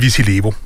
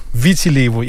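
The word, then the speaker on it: Viti Levu: Den hedder Vitilevo. – Vitilevo, yes. Jeg var lidt usikker på, hvordan man udtalte det.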